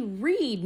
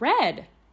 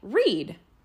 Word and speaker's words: read: It's actually read, not read. – But it's spelled red. – Yes, I know, but in this case, E-A is actually pronounced like an E-E, like read.